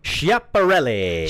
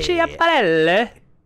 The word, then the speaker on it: Schiaparelli: Schiaparelli. – Schiaparelli.